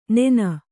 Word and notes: ನೆನ: Kannada

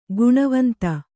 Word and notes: ಘನವಂತ: Kannada

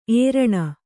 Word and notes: ಏರಣ: ēraṇa

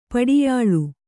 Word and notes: ಪಡಿಯಳೆ: Kannada

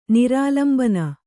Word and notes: ನಿರಾಲಂಬನ: nirālambana

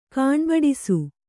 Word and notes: ಕಾಣ್ಬಡಿಸು: Kannada

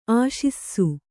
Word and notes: ಆಶಿಸು: Kannada

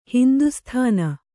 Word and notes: ಹಿಂದೂಸ್ಥಾನ: hindū sthāna

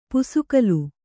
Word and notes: ಪುಸುಕಲು: pusukalu

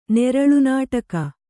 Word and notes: ನೆರಳುನಾಟಕ: Kannada

